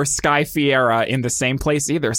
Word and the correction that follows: Ferreira: I'm pretty sure it's Ferreira.